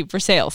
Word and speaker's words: Versailles: No, it's spelled like Versailles, but they say it in Kentucky, Versailles.